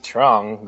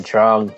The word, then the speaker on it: Truong: Then Betwane is spelled capital B-E-T-W-A-N-H-E. – Alright, Nick? – Mine is just Nick Trung. Like, just Nick with a C at the end. And then Trung, T-R-U-O-N-G.